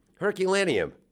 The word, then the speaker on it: Herculaneum: Herculaneum.